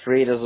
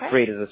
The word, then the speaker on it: Freitas: You can put either Lima Freitas or just Freitas. It's up to you. I think Freitas is fine. – How do you spell that? – F-R-E-I-T-A-S. – F-R-E-I-T-A-S? – Yeah, FREITAS.